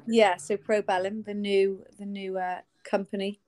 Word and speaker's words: Probellum: First thing I'm going to ask you, how do I pronounce it? Is it Probellum or Probellum? How am I pronouncing this? – Yeah, Probellum. – Right, Probellum, okay.